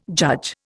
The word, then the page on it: judge: Indian